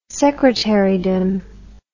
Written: secretarydom

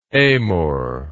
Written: amor

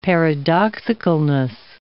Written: paradoxicalness

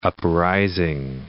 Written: uprising

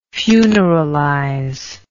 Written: funeralize

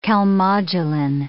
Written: calmodulin

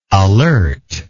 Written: alertness

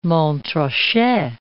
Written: Montrachet

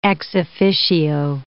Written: ex officio

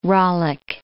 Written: rollick

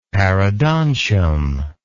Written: parodontium

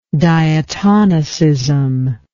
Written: diatonicism

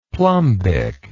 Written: plumbic

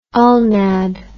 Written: ulnad

